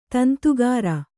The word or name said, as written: ದುಂದುಗಾರ